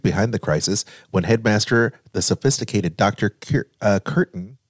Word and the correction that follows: Curtain: did I say that right? Curtain, appears to be behind the worldwide panic.